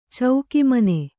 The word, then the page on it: ಚೌಕಿಮನೆ: cauki mane